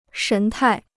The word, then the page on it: 神态: shén tài